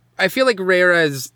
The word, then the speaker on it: Reira: We should establish a standard pronunciation here. – I feel like Reira is generally the like established, that's what most of the characters refer to her as.